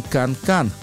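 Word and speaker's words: канкан: И на нем звучит, и в качестве сингла тоже вышла, знаменитая музыка Жака Оффенбаха под названием «Кан-кан» или «Кен-кен». Произносите, как хотите.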